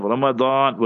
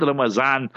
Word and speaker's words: Ramadan: So that is the meaning of Ramadan with the dua'at, not Ramazan, like how our people say, Ramadan. So that is how we must learn the pronunciation properly.